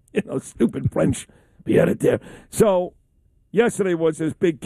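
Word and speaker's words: pied-à-terre: Pied a terre. – Say that again? – Pied a terre.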